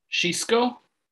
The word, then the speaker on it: Xisco: His name is spelled X-I-S-C-O. Does that sound like a decent pronunciation? – Xisco? – Xisco. – Yeah, that's what I would say.